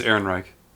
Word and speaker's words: Ehrenreich: Ehrenreich. – Like the Third Reich. – Ehrenreich. Yeah. Sure. I think it's Ehrenreich. – Mm-hmm. – That'll be the English pronunciation.